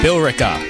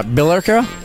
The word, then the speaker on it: Billerica: How do you pronounce it? Billerica. There's an E in there. – Billerica. – Billerica, maybe? – Billerica. – Oh, that makes sense. Just leave out the E.